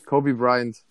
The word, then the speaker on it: Kobe Bryant: Kobe Bryant.